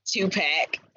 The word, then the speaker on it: Tupac: Tupac.